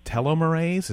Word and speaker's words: telomerase: And you have discovered an enzyme, a very special enzyme called telomerase.